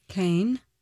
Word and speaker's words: Caen: And then there's another city called C-A-E-N, Cannes. – Cane. – And so that's the one that's more like Cannes. And then C-A-N-N-E-S is more like can, but whatever.